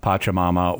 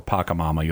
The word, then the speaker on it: Pachamama: When these things, so let's kind of take a step back and talk about who Pachamama or Pacamama, you'll hear it pronounced both ways, who that is.